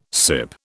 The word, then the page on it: sip: British English